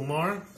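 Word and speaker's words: Maher: Вот, там, типа, этот главный чувак приходил на Билл Мар, шоу этот. – Майя. – Нет, произносится как Мар.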